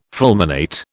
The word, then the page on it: fulminate: British English